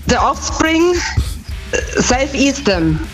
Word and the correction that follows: Self Esteem: Ja, die Aussprache ist ein bisschen... – Team is Team. – Ja. Ja, ist richtig. – Ja, Self Esteem nehmen wir auch.